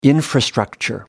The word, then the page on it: infrastructure: American English